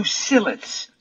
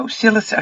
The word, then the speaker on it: psyllids: Let's go to psyllids. Now, psyllids are spelled with a P up front. It's a silent P, of course.